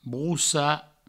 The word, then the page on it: brossar: broussa